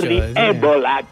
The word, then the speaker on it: Jordi Évole: Mujica auténtico, señor, con Jordi Évole. – Sí, correcto. – Ébola, en perdón, julio, en el lo dije mal. Uruguayo, Jordi Évole, que no, lo viene a exprimir una vez cada cuatro años.